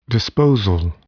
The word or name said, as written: disposal